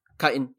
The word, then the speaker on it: chitin: Is that how it's yeah. pronounced? – chitin.